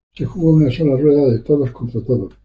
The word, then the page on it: rueda: spaans